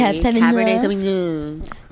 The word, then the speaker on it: Cabernet Sauvignon: No, say it correctly. Cabernet Sauvignon. – Sauvignon. – That's how we say it.